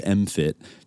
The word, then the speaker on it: MMFT: military have created and established the mindfulness-based mind fitness training, MMFT, pronounced MFIT, to, quote, "build resilience and optimize individual and team performance."